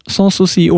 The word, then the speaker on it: Sanssouci: s a n s und S-O-U-C-I. Sanssouci, ohne Zweifel.